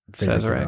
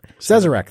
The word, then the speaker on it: Sazerac: Right, which is one of your favorites Sazerac. – Out there. Yep. Sazerac, thank you, owns Buffalo Trace.